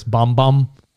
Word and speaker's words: Bam Bam: It's spelled Bam Bam, but it's Bomb Bomb.